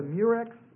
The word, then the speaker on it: murex: There was a certain dye, and it was this purple dye, and they got it from what was called a murex shellfish. Murex rhymes with purex. Spelled like this. And it was a little snail. It's spelled M-U-R-E-X.